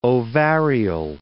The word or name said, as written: ovarial